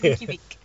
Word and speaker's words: Reykjavik: It's Reykjavik.